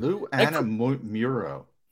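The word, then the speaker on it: Lou Anarumo: But it was that coordinator – I can't pronounce the guy's name. – Lou Anamuro.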